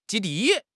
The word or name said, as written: 基地